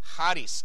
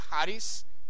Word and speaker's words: charis: It comes from this Greek word on the left, which is pronounced charis. Can you say charis? Charis.